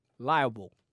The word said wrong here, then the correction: libel: No? Pronounce it then. You know it. – The word libel.